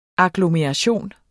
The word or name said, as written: agglomeration